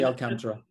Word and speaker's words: Alcantara: Yeah, Sandy Alcantara.